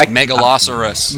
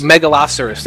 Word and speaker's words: Megalosaurus: You guys are crazy. It's pronounced Megalosaurus. – Megalosaurus, there you go.